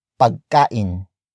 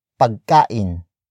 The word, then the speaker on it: pagkain: It is the object marker that introduces the non-focus noun of this sentence, and that is pagkain. Pagkain. Pagkain. Pagkain is spelled as P-A-G-K-A-I-N.